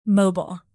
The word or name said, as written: mobile